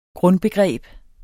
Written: grundbegreb

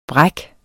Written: bræk

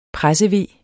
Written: presseve

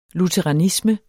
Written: lutheranisme

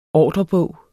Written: ordrebog

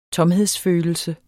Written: tomhedsfølelse

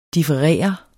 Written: differer

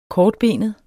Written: kortbenet

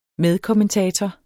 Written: medkommentator

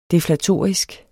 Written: deflatorisk

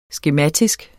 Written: skematisk

